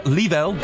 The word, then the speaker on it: Leavell: Born 1952, Chuck Leavell. Leavell, get his name right, Steve.